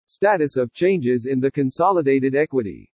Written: STATUS OF CHANGES IN THE CONSOLIDATED EQUITY

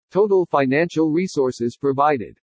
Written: TOTAL FINANCIAL RESOURCES PROVIDED

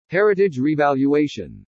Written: HERITAGE REVALUATION

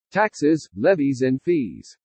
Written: TAXES LEVIES AND FEES

